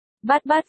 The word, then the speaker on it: 88: 88, miêu miêu, à, 88, trích dẫn, 88, số 8 trong tiếng Trung đọc là bát 88. Bát bát phát âm gần giống bye bye.